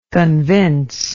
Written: convincibility

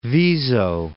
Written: Viso

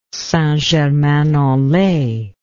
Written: St Germain en Laye

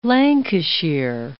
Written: Lancashire

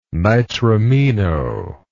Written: nitramino

